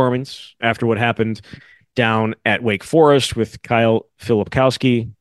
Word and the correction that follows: Filipowski: Is that how you say it? Filipowski? – Filipowski. I'm going to leave it to you.